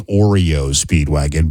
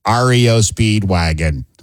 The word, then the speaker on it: REO Speedwagon: When I was younger and I heard people pronounce REO Speedwagon, I thought they were saying Oreo Speedwagon, but with like an East Coast Philadelphia accent. REO Speedwagon. No, it's REO Speedwagon, not Oreo, you dummy.